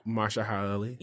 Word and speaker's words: Mahershala Ali: Yes, I feel like, pronounce his name for me again. – Mahershala Ali. – Yes.